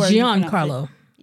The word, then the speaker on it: Giancarlo: Juan Carlo, that's how you say it. – It's pronounced something. He like makes sure. – Giancarlo. Yes. Giancarlo.